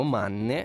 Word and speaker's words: Manne: Molti ascoltatori hanno curiosità linguistiche e quindi il cognome è di origine austriaca, quindi lo pronunceremo Manne. Non abbiamo idea di come lo pronunciano gli americani, ma ci va bene così.